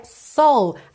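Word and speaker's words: Seoul: Aí uma dica, que Seul em inglês se pronuncia Soul, assim como a alma ou a Soul Music, S-O-U-L, Soul, ok?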